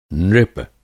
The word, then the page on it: नृप: Hindi